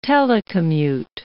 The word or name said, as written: telecommute